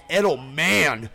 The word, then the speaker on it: Edelman: It's pronounced Edelman.